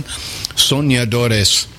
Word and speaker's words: Soñadores: You got a good pronunciation there, Sean. Soñadores.